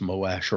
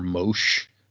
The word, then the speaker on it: Moash: Thanks, I guess, Moash. – Appreciated, question mark? – I heard some debate about whether it's pronounced Moash or Mosh. And I was just like, I can't imagine saying Mosh.